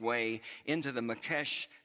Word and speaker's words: Mokesh: M-O-K-E-S is the way it's rendered in English, but pronounced Mokesh.